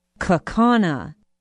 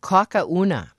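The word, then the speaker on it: Kaukauna: asked me to send her some cheese from Kakauna. I thought that was the best mispronunciation that I had heard in a long time.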